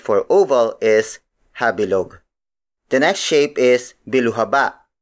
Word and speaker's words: habilog: The next word is habilog. Habilog. Habilog. Habilog is spelled as H-A-B-I-L-O-G.